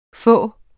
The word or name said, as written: få